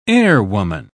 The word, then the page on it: airwoman: British English